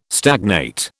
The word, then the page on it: stagnate: British English